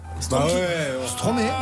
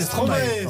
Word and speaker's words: Stromae: Stromae, non, ça marche pas, c'est Stromae. – Stromae, je connais pas. – Il manquait des syllabes.